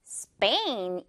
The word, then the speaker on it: Spain: 자, 마지막 단어는 이거 나라 이름인데 스페인이라고 우리는 표기하고 말하지만 역시 영어 발음상은 Spain 이렇게 발음을 해줘야 자연스럽습니다. Spain. 이거 그냥 Spain이라고 하는 원어민은 영어 원어민은 없다고 보면 돼요. Spain. 빼 발음.